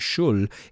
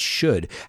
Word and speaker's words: should: So how often should you, remember, we don't pronounce the L, it's not shul, it's should.